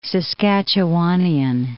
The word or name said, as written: Saskatchewanian